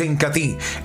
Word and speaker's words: Incatea: Se escribe Incatea, pero se pronuncia Incati, en presentación de medio litro por solo 39 mil pesos.